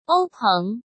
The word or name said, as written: Ou Peng